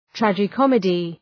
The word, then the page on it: tragi comedy: ,trædʒı’kɒmıdı